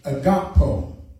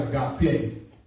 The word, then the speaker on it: agapao: The Greek word for love in John 3:16 is agapo. Agapo. A-G-A-P-A-O. Not agape, but agapo. No, it's agapo.